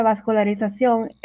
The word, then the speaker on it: vascularización: Esta palabra vascularización es una palabra aguda si se acentúa en la última sílaba debido a que termina en N.